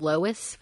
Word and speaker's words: Lois: Well, we learned that before staying at the hotel, she called and said she'd be staying with a guy named Lewis Fairgate, spelled Lois but pronounced Lewis.